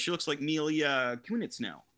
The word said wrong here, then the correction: Kunis: Oh, Mila Kunis, yeah.